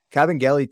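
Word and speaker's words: Kabengele: So I think with Cabangele, Adam, also shout out to you for pronouncing the G with the hard G. Cabangele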